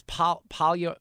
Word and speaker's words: polyamory: Fred, do you know how to pronounce it? By the way, it's polyamory. Polyamory.